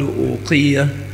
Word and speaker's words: أوقية: الأواق جمع أوقية، يقال أوقية بضم الهمزة وكسر القاف وتشديد الياء آخرها تاء مربوطة، وكثير من الناس ينطقها بدون همزة، وبالأخص العامية.